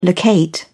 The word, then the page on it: locate: British English